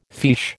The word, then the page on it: fiche: American English